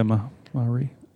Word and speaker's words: Maori: Maori, is that how you say it? – Yeah, Maori.